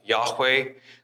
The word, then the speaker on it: Yahweh: In this case, the tetragrammaton, often pronounced Yahweh.